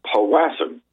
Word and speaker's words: Powassan: The disease, known as, and I think you pronounce it, powassan, P-O-W-A-S-S-A-N.